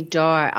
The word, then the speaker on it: Doerr: I'm sure your bookshop sold dozens of copies by, is it Anthony Doerr? Is that how you pronounce it? – Anthony Doerr, I understand, is how he pronounces it.